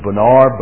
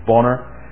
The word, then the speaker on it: Bonar: Next week we want to think about Andrew Bonner. Also sometimes his name is a bit of a contention as to how to pronounce it. Sometimes pronounced Andrew Bonar, but it was Andrew Bonner,